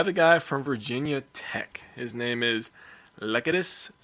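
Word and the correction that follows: Lecitus: Smith. I'm not sure I said that right. – That's Lacetus. – Lacetus? – It's Lacetus.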